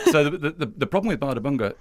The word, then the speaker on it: Bárðarbunga: Bárðarbunga. – Bárðarbunga, yes.